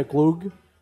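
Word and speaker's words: glögg: We learned how to make, in Sweden, I believe it's spelled G-L-O with the little line through it, G-G, a glug.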